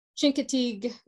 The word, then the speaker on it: Chincoteague: Chincoteague, Virginia.